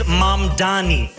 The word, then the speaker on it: Mamdani: The name is Mamdani. M-A-M-D-A-N-I. You should learn how to say it.